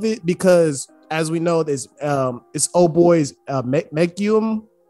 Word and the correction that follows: Megumi: Is how you pronounce his name? – Megumi.